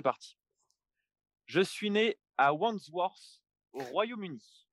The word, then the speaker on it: Wandsworth: Wandsworth.